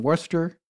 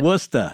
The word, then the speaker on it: Worcester: So Edward Kelly, or simply Kelly, was also known as Edward Talbot, and he was born in Worcester in August of 1555 and died in 1597 or 1598.